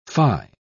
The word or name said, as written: fie